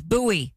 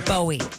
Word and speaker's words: Bowie: And if you're local, you know how to say it. It's Bowie, not Bowie.